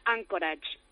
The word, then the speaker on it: Anchorage: Anchorage, me parece que se dice. ¡Hola! – Sí, hola, ¿qué tal? – ¿Cómo estás? – Muy bien, y se pronuncia Anchorage.